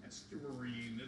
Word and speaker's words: estuarine: and estuarian biology. And today we have the curator of estuarian biology, the biology section here. Did I say that right? Estuarian? – Estuarine.